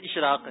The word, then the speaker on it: اشراق: اشراق یا اشراق؟ – اشراق، ویسے مرکز الاولیاء میں تو اشراق کہتے ہیں. – درست اشراق ہے.